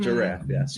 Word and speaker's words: giraffe: He keeps saying giraffes like one syllable, and giraffe. I've had to explain to him. Like it is two syllables. – Thank you. – Giraffe. – Mm-hmm. Giraffe, yes.